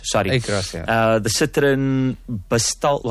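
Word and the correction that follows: Basalt: How do I say that? – Basal. – Basal. There we go.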